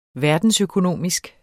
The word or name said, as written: verdensøkonomisk